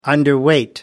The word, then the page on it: underweight: American English